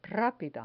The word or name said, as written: rapida